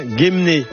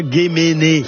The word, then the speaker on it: Gemené: Donc, soutien à nos andouilles, évidemment, en espérant pouvoir continuer à en manger, comme celle de Gemné. Et on dit bien Gemné, et pas Gemené. Non, Gemné.